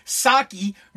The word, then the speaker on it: Psaki: Is it Psaki? It's not Psaki. It's the silent P. Psaki. We need Psaki.